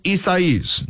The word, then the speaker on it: Isaias: Also, we are continuing to monitor Tropical Storm Isaias. Now, there's a lot of different pronunciations out there. I got Isaias, I'm going to tell you, from the National Weather Service.